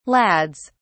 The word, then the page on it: lads: British English